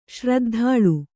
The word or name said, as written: ಶ್ರದ್ಧಾಳು